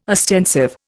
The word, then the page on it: ostensive: Indian